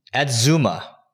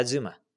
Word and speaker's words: Adzooma: A-D-Z-O-O-M-A. – AdZuma. – Yeah, AdZuma.